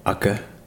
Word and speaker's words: Aceh: Aceh.